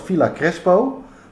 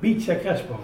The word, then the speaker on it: Villa Crespo: Dat ligt in, op zijn Nederlands Villa Crespo. Maar dat klinkt een beetje Efteling-achtig. Hoe spreek je dat in het Spaans uit? – Uh, Beach Crespo.